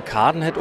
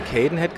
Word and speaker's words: Cadenhead: Ich habe irgendwann mal einem verdienten Mitarbeiter versprochen, Cadenhead zu sagen, weil das natürlich die wirklich vernünftige Aussprache ist, weil ein A im Schottischen tatsächlich wie ein A ausgesprochen wird.